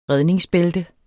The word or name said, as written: redningsbælte